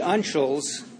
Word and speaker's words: uncials: And whether they're, how do you say this, Nancy? Unctual? Unctual, excuse me. The uncials are the capitals.